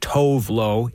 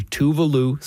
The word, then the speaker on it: Tove Lo: Now you look at the name on your screen or on paper and you think it's pronounced Tove Lo. It's actually Tuvalu, singer-songwriter from Sweden.